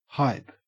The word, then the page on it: hype: Australian English